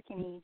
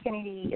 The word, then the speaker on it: Kennedee: On Facebook, under author Kennedy DeVoe, and Kennedy is spelled K-E-N-N-E-D-E-E,